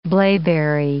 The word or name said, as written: blaeberry